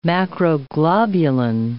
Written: macroglobulin

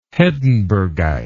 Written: hedenbergite

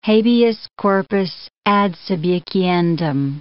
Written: habeas corpus ad subjiciendum

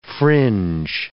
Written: fringe

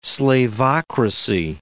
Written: slavocracy

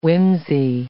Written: whimsey

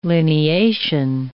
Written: lineation

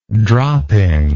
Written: dropping

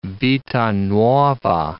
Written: vita nuova

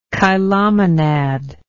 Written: chilomonad

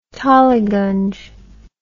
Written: Tollyganj

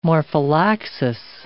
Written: morphallaxis

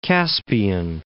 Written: Caspian Gates

